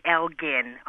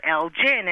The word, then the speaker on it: Elgin: In the U.K., it is definitely Elgin, a hard G. But here in the U.S., we like soft Gs, and you usually do hear Elgin.